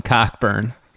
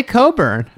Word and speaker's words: Cockburn: Hey, is it really pronounced Coburn, or are you just saying that? – That's my dad refuses to pronounce it Coburn. He calls him Cockburn. – I mean, what's it pronounced? How's it supposed to be pronounced? – They say Coburn.